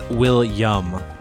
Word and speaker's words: Willyum: It's Will-yum because it's just so good. – Will-yum. – W-I-L-L-Y-U-M? – Yes.